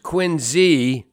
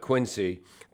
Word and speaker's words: Quincy: It's pronounced Quincy, not Quincy, Quincy, because that's the way the Quincy family pronounced their name with the British pronunciation. Any city named Quincy that is supposed to honor a member of that family should pronounce it correctly with the Z pronunciation.